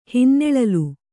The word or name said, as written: ಹಿನ್ನೆಳಲು